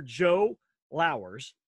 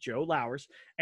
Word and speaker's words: Lowers: We have the CEO and the owner, the guy who runs and has set up the entire World Series of Comedy, Mr. Joe Lowers, like flowers without the F. So when you think about it, if you want to know, that's how you say it, Joe Lowers.